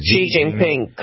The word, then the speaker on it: Xi Jinping: Did I pronounce that right? I never know that I pronounced it right. – Xi Jinping, correct.